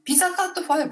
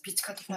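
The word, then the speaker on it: ピッチ カ ット5 (ピチカート・ファイヴ): で、トムさんが送ってくれて、 YouTube で、でこう iPhone でこう見てて、文字も小さいし、なんかこれなんて読むのかっていうのがパッと自分の頭の中にピザカット5 って読んでしまったんですね。で、ピザカット 5? とかパッと見ていって、それをカナさんに言ったら、告白したら、実は私ピッチカット5 って読むと知らなくてピザカット 5と読んでいたんだって言ってしまったら、じゃあカナさんが次の曲を選んでくれました。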